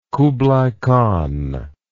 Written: kublai khan

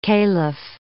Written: caliph

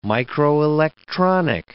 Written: microelectronic

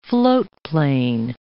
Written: floatplane

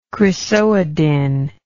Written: chrysoidine

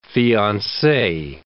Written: fiancee

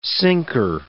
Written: sinker

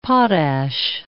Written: potash

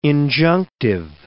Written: injunctive

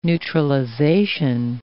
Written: neutralization

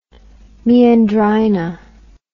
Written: meandrina

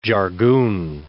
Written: jargoon

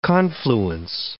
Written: confluence

